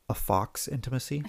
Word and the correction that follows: faux: Faux. – No, fox. – No, faux. – No, it's spelled F-A-U-X. – Yes, but it's French and it's faux, pronounced faux, dear.